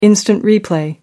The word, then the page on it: instant replay: American English